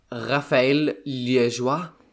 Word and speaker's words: Raphael Liégeois: Næste i rækken, det er en belgier, Raphael Liégeois. Håber jeg, det er, det er korrekt udtalt. Yep.